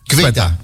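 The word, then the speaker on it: Ceuta: Cueta, hoe spreek je dat? – Cueta.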